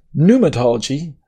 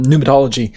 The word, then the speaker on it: pneumatology: And when we talk about pneumatology or pneumatology, if you want to pronounce the P, um, give me a second.